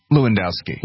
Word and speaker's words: Lewandowski: I always pronounce his name wrong. It's Corey Lewandowski. Is that right? – Lewandowski.